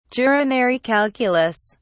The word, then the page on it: urinary calculus: British English